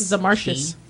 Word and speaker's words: Zemarcuis: Z-E-M-A-R-C-U-I-S. Zemarcus? Zemarcus? – Zemarcus.